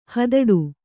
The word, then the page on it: ಹಿದೌಠ: kannada